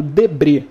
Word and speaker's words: Debret: Um Google fácil e rápido, num cara chamado Debre. Se escreve Debret né?